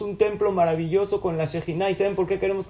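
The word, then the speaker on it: Shejina (שכינה): la Shejina, que es Shejina. Shejina se escribe Shohen Yut He, que Kadosh Baruchu posa y se ve claramente entre nosotros.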